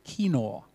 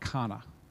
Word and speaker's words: Qana: I haven't got all the accents in there with the Q-A-N-A, but it is pronounced more like Kinor, not Kana.